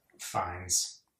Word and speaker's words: Fiennes: Fiennes. – Ralph Fiennes. Yeah, who's that? – Voldemort. – Yeah. – I know who he is. You said his name wrong.